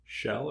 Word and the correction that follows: shale: Shale. – Okay, you said shell. – Shale. I have a really hard time pronouncing that. I am sorry. It's not shell. It's shale.